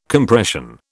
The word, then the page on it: Compression: British English